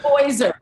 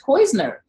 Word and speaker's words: Poyser: I said Poyser, okay? – No, we call him Poyser. – Say his name, Mariah. Say his name. – I'm just saying, I said James Poyser because everybody else says Poisner.